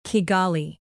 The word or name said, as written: kigali